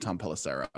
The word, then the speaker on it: Tom Pelissero: Tom Pelissero, excuse me. Messed up his pronunciation, so I want to get that right. Tom Pelissero.